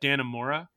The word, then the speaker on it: Dannemora: I just, you know, anecdotally, I was thinking about how I remember Escape at Dannemora.